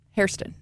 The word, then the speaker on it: Hairston: So we talked this morning about how Bill's first round pick, Max Hairston. Did I say that right? – Hairston.